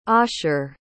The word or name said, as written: osher